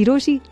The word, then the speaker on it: Rochie: मेरा नाम है मिर्ची रोशी, नाम के स्पेलिंग है RoCHIE.